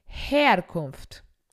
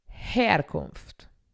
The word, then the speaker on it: Herkunft: En este caso inicia h e Herkunft. Entonces vamos a pronunciar la H con la E, como una J y la E nosotros en español. Origen, Herkunft, Herkunft.